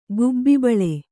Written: ಗುಬ್ಬಿಬಾಳೆ

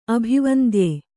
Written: ಅಭಿವಂದ್ಯ

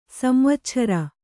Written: ಸಂವಚ್ಛರ

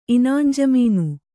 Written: ಇನಾಂಜಮೀನು

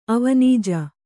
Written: ಅವನಿಜ

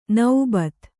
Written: ನೌಬತ್ತು

